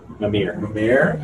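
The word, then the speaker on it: Mímir: Mimir? – Mimir, okay.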